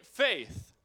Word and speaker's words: faith: Alla säger faith. faith. tro. Alla säger tro. Det är lite enklare. Eh, det engelska eh, ordet faith är lite svårt att uttala, precis som youth med TH.